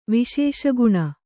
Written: ವಿಶೇಷಗುಣ

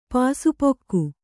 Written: ಪಾಸುಪೊಕ್ಕು

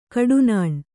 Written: ಕಟ್ಟನ್ನ

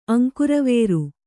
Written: ಅಂಕುರವೇರು

